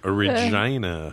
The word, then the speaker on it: Regina: Because you were in Regina. – Regina. – Which rhymes with fun. – Regent. – Do you know that there's a big billboard that says... – Regina rhymes with fun.